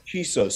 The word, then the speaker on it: Chisos: Well, his name is Chisos, C H I S O S.